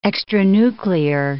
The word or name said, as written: extranuclear